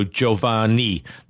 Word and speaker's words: Giovanni: So Italian, Gio is Joe, not G-O. So it's also Giovanni, not Giovanni.